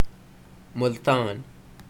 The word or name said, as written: Multan